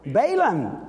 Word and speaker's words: Balaam: The teaching of Balaam? How do you say it? Balaam, okay, and the other name is very the Nicolaitans.